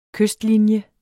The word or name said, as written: kystlinje